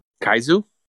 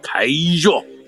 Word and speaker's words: Kyuzo: All right, so his species is spelled K-Y-U-Z-O. – Kaizu? Kaizu? Kaizu.